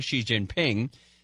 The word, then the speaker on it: Xi Jinping: Speaking of North Korea, the president tweeted today that he received a message from the president of China, Xi Jinping.